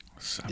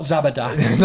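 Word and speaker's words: Zabiják: The Czechoslovakian love of McBain adaptation starts in this year with a thing called Zabijak. I think that's that's pronounced. Which I think is based on Cop Hater. Zabijak. It's made for TV. Not Zabadak.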